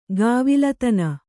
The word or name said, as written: ಗಾವಿಲತನ